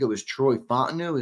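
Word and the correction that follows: Fautanu: Fatano. – Fatano, that's right.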